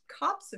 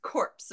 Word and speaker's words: copse: and I've always pronounced copse of trees as corpse of trees, which apparently is not how you say it.